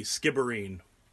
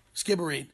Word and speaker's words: Skibbereen: There was an American visitor he visited a small town in Ireland called Skibbereen. How do you say it? – With a B, Skibbereen. – Skibbereen.